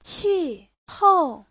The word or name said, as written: qi4hou4